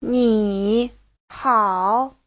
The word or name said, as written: ni3hao3